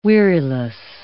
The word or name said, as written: weariless